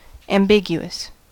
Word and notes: ambiguous: American English

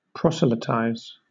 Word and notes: proselytize: British English

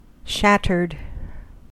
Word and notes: shattered: American English

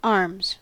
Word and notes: arms: American English

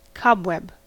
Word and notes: cobweb: American English

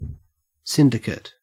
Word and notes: syndicate: Australian English

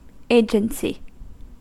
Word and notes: agency: American English

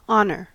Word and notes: honor: American English

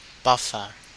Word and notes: buffer: Australian English

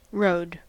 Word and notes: rode: American English

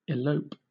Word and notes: elope: British English

